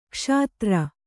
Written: ಕ್ಷುದ್ರ

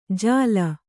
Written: ಜಾಲ

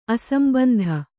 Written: ಅಸಂಬಂಧ

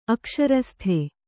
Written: ಅಕ್ಷರಸ್ಥ

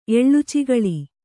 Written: ಎಳ್ಳುಚಿಗಳಿ

